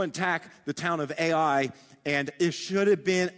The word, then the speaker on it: Ai: That they saw this little town in the distance called Ai. It's literally just AI. That's how it's spelled, AI.